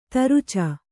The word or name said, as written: ತುರಚೆ